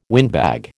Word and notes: windbag: American English

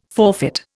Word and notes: forfeit: Indian

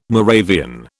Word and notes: Moravian: British English

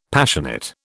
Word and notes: Passionate: British English

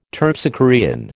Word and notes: Terpsichorean: American English

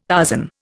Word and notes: Tarzan: Indian

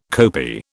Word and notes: kopi: British English

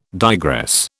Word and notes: digress: British English

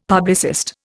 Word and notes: publicist: Indian

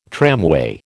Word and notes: tramway: American English

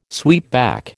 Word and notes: sweepback: American English

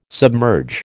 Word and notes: submerge: American English

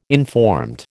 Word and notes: informed: American English

